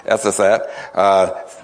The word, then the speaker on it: Ephrathah: But you, Bethlehem, and as he asked me how to pronounce the next word, Ephrathah,